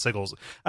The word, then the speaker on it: sigil: It's pronounced sigils. It's sigil. It's sigil, but they spell it with a U here and that throws me off.